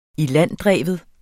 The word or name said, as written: ilanddreven